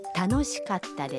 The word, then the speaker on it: 楽しかった: 请注意カッタ的发音，要在カ和タ中间停顿一拍。 – 楽しかったです。